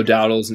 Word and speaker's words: Dowdle: Is it Dowdle? – It's only a matter of time before we get, you know, people commenting like, does this dude actually know how to pronounce anyone's name? – Dowdle, I think it's endearing. Dowdle. It's Dowdle.